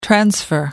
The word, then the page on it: transfer: American English